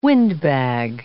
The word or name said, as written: windbag